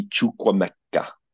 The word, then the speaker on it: Chukwuemeka: Someone else say his name for me, please. I can't pronounce it. – Carney Chukwuemeka. – There we go.